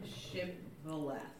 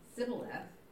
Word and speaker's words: Shibboleth: Shibboleth. But he said, "Sibboleth," for he could not pronounce it correctly.